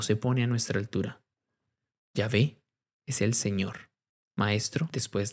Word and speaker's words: Yahvé: No tiene vocales, así que cuando tratamos de pronunciarlo, suena como Yahvé o Jehová.